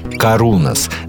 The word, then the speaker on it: Karunos: Первая группа сегодня «Карунас», далее цитирую, произносится с ударением на «у», группа из Санкт-Петербурга, история которой началась глубоко в Сибири и продолжается на берегах Невы.